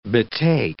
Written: betake

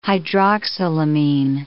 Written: hydroxylamine

